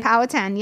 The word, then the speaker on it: Powhatan: And then that was a turning point where then, you know, the Powhatan, is that, am I pronouncing that correctly? – Powhatan, yeah.